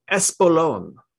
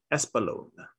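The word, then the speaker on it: Espolòn: Espolon. Espolon is what it's called. I think that's how it's pronounced.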